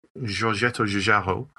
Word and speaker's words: Giorgetto Giugiaro: So a little bit like Giorgetto Giugiaro, which I'm told is an all right pronunciation of it. by a genuine 100% Italian, I'll have you know.